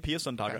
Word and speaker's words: Pia Sundhage: That's her name, Pia Sundhage.